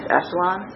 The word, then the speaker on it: echelon: So another kind of weird matrix lingo is that you're going to see is the wording row echelon, the pronunciation is echelon, row echelon form.